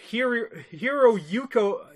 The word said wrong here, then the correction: Hiroyuki: No, sorry. Kerry Hiroyuki Tagawa.